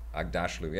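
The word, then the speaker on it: Aghdashloo: But the president of Earth is Shorey Angdalai. How do you say her name? – Oh, you're way off. – Shorey Agdasalu. – I'm going to try Agdasalu. – Now. – Okay. – Shorey Agdasalu. – Agdashlu.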